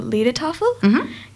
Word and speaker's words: Liedertafel: Our school band performed a few songs, and if I'm correct, it's pronounced the Liedertafel, came to our school to do a couple of traditional German songs. – And that's some mature age. – The Tanunda Liedertafel? – Yes.